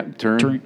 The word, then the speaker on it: Turin: Yeah, Turin.